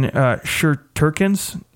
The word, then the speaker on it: Shesterkin: really good prospects in Shesterkin's, Igor, I don't even know how to pronounce his last name, and then Gorgiev.